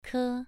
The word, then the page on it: ke: 2